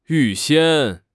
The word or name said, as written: 预先